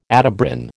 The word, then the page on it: Atebrin: American English